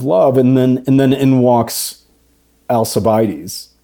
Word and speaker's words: Alcibiades: But then it's so interesting to me that the whole thing ends, and I never pronounce his name correctly, but Alcibiades, is it a hard C? – Probably in Greek it's a hard C, and then we say Alcibiades because we're Alcibiades, like Frankish.